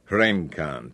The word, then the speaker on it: wrencan: Our word is from Old English wrenkan, W-R-E-N-C-A-N, with the C pronounced as a K. Wrenkan, to twist. Ultimately, by way of Germanic, the root is Indo-European W-E-R, where, in the zero grade or vowel-swallowed variant, W-R.